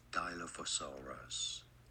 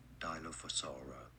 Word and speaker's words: Dilophosaurus: This is how it's pronounced. – Dilophosaurus. Dilo, not Dilo, but rather Dilophosaurus.